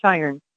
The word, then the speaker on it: Sheeran: it's a long I, so it's Sheeran.